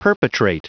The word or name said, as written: perpetrate